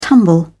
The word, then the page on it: tumble: British English